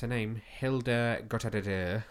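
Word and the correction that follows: Guðnadóttir: It's a very difficult surname to pronounce. Look, I'm going to show you it on my phone. So it's Gudnadottir. Gudnadottir.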